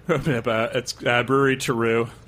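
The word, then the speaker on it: Terreux: It's Bruery Taru. Taru means earthy in French.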